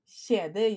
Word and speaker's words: kjede: So if yeah. you say skede, that's the vagina. And if you say it right, kjede, you're smiling. Kjede, that means a chain or like a necklace.